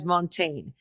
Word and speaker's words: Montagne: Now, he Americanizes as Montaigne. So I'm trying to be hip to the jive here, right? And pronounce his name correctly.